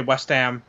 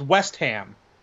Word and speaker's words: West Ham: I don't heavily pronounce the H, but I just say West Ham. But the annoying thing is when Americans emphasize the West, so it's West Ham.